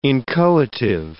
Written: inchoative

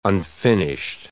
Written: unfinished

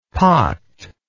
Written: pocked

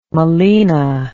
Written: melena

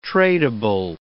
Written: tradeable

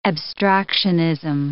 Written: abstractionism

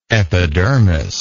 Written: epidermically

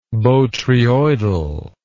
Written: botryoidally

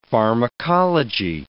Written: pharmacology

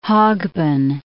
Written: Hogben

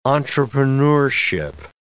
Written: entrepreneurship

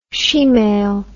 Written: shemale

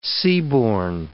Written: seaborne

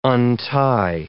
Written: untie

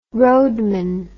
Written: roadman

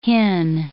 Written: hin

